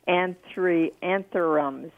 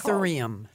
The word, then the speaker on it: Anthurium: I want to know about my anthuriums or whatever it's Anthurium. – Called. Sorry? – Anthurium. – Anthurium. Oh, okay. I could never pronounce it.